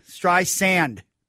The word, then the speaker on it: Streisand: Stry Sand. – Sand. Oh man. It's like bison with an S.